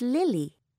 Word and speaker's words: Lily: It's Lily. L-I-L-Y.